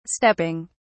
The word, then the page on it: stepping: British English